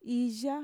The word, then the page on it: iya: Mixtec